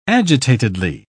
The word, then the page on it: agitatedly: British English